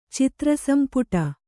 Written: ಚಿತ್ರಸಂಪುಟ

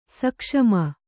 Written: ಸೂಕ್ಷುಮ

